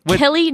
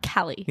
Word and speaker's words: Kelly: Yeah, so it's Kelly, not Callie.